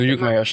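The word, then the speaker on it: Llucmajor: Und wir müssen ja sagen Luke Major, ne? – Das wird mir... Luke Major wird das ja ausgesprochen. Luke Major, stimmt.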